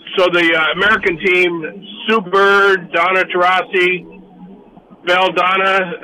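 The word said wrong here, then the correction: Delle Donne: WNBA most valuable player in the last 2 years. – Yeah, Elena Delladonna.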